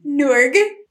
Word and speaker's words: Norge: We're going to go with Norge.